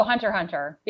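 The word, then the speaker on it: Hunter x Hunter: Oh, Hunter Hunter, because it's got that silent X.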